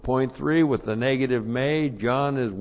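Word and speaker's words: may (μή): And then point three, when you have with this the negative, which we do, the negative may, not your mule may, but this is may, M long E.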